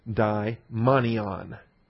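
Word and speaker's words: daimonion (δαιμόνιον): D-A-I-M-O-N-I-O-N, daimonion. Those don't look like N's, I know. Those look like V's. But those are N's. The Greek letter nu. So it's pronounced daimonion.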